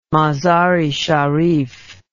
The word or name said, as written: Mazar-i-Sharif